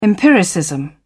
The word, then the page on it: empiricism: British English